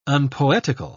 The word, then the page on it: unpoetical: British English